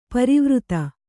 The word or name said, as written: ಪರಿವರ್ತ